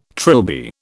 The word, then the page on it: trilby: British English